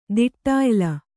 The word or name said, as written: ದಿಟ್ಟಾಯ್ಲ